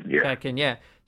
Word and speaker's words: Shatkin: It's Jesse, how do you pronounce his last name? Shatkin, is that right? – Shatkin, yeah.